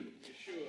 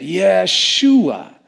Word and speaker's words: Yeshua: How do you say Jesus' name in Hebrew? Yeshua. Yeah, everybody say it. Yeshua.